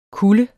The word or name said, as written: kulde